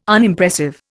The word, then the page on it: Unimpressive: Indian